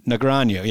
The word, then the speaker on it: Negreanu: Negreanu, yes.